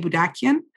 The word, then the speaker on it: Boudakian: And my last name is spelled B like boy, O-U-D like Donald, A-K-I-A-N like Nancy, Reneé Boudakian.